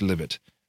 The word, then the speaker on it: Libet: In a now famous or perhaps infamous experiment by Benjamin Libet, is it Libet? I think it's pronounced Libet.